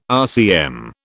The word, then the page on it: RCM: British English